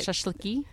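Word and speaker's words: Shashliki (шашлыки): I, they're they're very romantic sounding words, like the shashlika uh, and the. That, did I pronounce that right? Shashliki. – Yeah, like the, the plural the, of shashlik.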